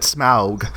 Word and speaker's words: Smaug: I know two things, that goblins don't have blood, and then you pronounce it Smaug. Okay? – Just like Sean Connery would say it.